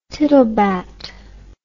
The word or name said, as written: tittlebat